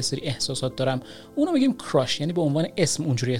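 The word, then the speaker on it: crush: با تلفظ اشتباه کراش خیلی اینو استفاده میکنن که کراش در واقع تلفظ باید بشه نباید بگید کراش که در واقع این تلفظ فارسی این کلمه هست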